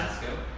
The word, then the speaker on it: Tasko: Tasco. – Would you spell it for? – T-A-S-K-O.